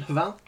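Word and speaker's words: Hval: That's pronunciation, Haval.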